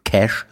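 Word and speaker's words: Cache: Spotify-System, also meins auf jeden Fall nicht gerafft, selbst wenn man den Cash, so spricht man es aus, äh, leert. Irgendwie, ich weiß gar nicht, ob es jetzt geht. Kache, Kache, Kache am, Blut, am, am Computer.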